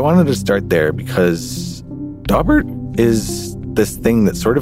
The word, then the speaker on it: Daubert: Daubert. – Daubert. Or as it's often pronounced, Daubert.